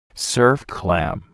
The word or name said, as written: surf clam